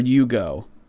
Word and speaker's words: Uggo: How would you pronounce U-G-G-O? Uggo or Ugo? – Either.